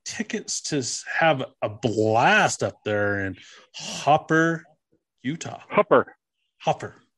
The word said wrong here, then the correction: Hooper: Hooper. Spelled H H O O P E R. I say Hooper.